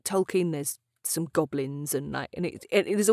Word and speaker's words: Tolkien: First of all, I discovered that I've been pronouncing Tolkien wrong my entire life. It is pronounced Tolkien.